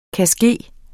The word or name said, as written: kanske